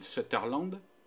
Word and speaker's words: Sutherland: qui est incarné par Donald Sutherland. Voilà, je l'ai bien prononcé. – T'as décidé de le prononcer correctement parce que sinon tu l'appelles comment? – Sutherland. – Voilà. Sutherland. Ok, ouais.